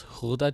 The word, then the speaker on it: Gouda: And while we're speaking of Holland, some of the most famous Dutch creations came from the Holland region, such as Gouda cheese, which apparently is pronounced Gouda cheese because it's Dutch.